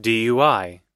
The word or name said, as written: dui